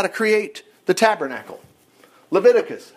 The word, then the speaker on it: Leviticus: Leviticus, we miss it. Leviticus, Levi. Totally miss it when you pronounce it that way. It's Leviticus.